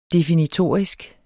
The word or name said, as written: definitorisk